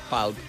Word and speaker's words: Pulp: Bé, pulp, no sé, palp. – Sí, en altre llegim pulp, que és també el que deia jo, però realment s'ha de pronunciar palp.